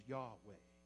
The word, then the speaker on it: Yahweh: But the way we pronounce it these days is Yahweh.